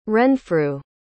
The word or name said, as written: renfrew